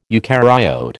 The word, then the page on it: eucaryote: American English